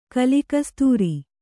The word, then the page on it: ಕಲಿಕಸ್ತೂರಿ: kalikastūri